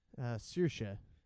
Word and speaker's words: Saoirse: How do you say Saoirse Ronan again? – Saoirse.